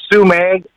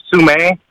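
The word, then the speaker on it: Sumeg: So Sume, you kind of have a soft G at the very end, but if you just say Sume, that's just perfectly fine.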